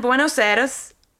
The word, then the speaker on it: Buenos Aires: So he's born in Buenos Aires, Argentina.